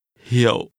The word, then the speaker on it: hiệu: Hiệu. – This is pronounced with a low, falling, broken tone.